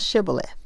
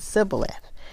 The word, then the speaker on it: Shibboleth: They had to pronounce Shibboleth, and they pronounced it Sibboleth.